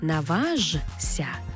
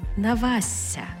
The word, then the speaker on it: наважся: Тобто не «наважся», а «навася».